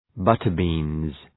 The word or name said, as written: butter-beans